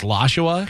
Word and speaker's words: Lashua: His you know name, it's yeah, it's on Twitter, and I believe his last name is Lashua because it's spelled like Joshua but with an L L A okay S. Uh,